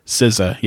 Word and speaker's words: SZA: SZA, yes, SZA.